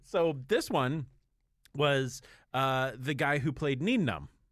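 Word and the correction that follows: Nien Nunb: Neenum. Ninenum. Ninenum is how you actually pronounce it.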